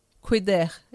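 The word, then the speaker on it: Kuider: Eu esqueci o nome, como que eu pronuncio. É Coider. – Cuider. – Cuider, isso.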